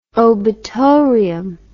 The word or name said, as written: obitorium